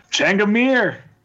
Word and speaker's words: Changamir: How do you say his name? – Changamir. – Changamir, okay. – Changamir. – I don't know. – You just say it with such confidence that it sounds like it's right.